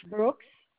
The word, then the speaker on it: Brooks: Brooks.